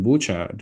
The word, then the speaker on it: Bucha: You know, the chief Russian negotiator in Istanbul, Medinsky, even said that the Ukrainians made up the massacre in Bucha because it rhymes with the English word butcher, and so this was all for the Anglo-Saxons' benefit.